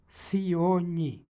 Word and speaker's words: Sionji: Ovo je prisvojni pridev i kod prisvojnih prideva dolazi do umekšavanja krajnjeg suglasnika osnove i ovdje imamo dakle nj izgovor. Sionji.